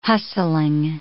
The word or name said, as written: hustling